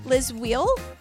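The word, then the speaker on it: Lis Wiehl: Her name is like, it's L-I-S and her last name is W-I-E-H-L. How do you say that? – Liz Wheel? – Okay. Okay, great.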